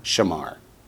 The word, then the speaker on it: shamar: And then Shamra becomes, how do you say he guarded? – Shamar, right.